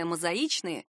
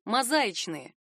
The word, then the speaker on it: мозаичные: Прилагательное «мозаичные» произносится именно так, с ударением на «и». Часто можно услышать другой вариант – «мозаичные», но словари не оставляют ему шанса и не отмечают даже как допустимый.